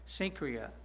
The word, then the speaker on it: Cenchrea: That's the way that Bible teachers tell me how to pronounce it, Cenchrea.